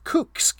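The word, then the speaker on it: Kukës: Meanwhile, our other team, I want to make sure I get the pronunciation right, is Kukes, K-U-K-E-S.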